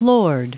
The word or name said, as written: lord